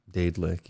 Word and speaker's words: Daedalic: I think it's Daedalic Entertainment, or Dadlic. – How do you spell it? – Or Deadlic. Daedalic Entertainment. – Daedalic, yeah.